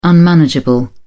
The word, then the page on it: unmanageable: British English